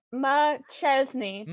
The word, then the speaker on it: McChesney: Do I pronounce your last name McKesney? Have I been saying that right? – No. – Oh, fuck. – You've been saying it wrong. – How do I say your last name? – McChesney. – McChesney, like a chimney.